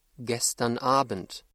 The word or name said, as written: gestern Abend